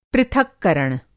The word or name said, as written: पृथक्करण